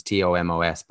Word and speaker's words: Tomos: The before I start onto what I was talking to you about, I just want to, like, commend Cardiff, because Tomas Williams, I don't know how it's pronounced, it's Tomos, but it's Tomas Williams and Halaholo actually played the perfect game for a team that was lost its two tens.